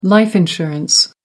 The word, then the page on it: life insurance: American English